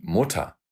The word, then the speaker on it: Mutter: Die Mutter – мама. Слышите? Никакого R, хотя на конце слова четко стоит R. M-U-T-T-E-R. А читается как мота.